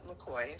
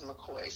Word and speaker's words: McCoy: So my name is Justin McCoy, so it's J-U-S-T-I-N, and last name's McCoy, so M-C-C-O-Y.